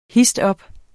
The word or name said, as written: histop